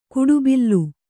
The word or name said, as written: ಕಡುಬಲಿ